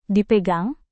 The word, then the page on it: dipegang: Indonesian